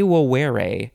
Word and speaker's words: vovere: There's two V's in there, which are pronounced like W's in Latin. De vovere, which is from de plus vovere, which means to vow.